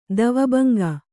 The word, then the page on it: ದ್ವಿಭಂಗಿ: dvi bhaŋgi